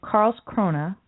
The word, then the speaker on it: Karlskrona: It says, Sweden Democrat Party politician Michael Hess of Karlskrona, I probably mispronounced that.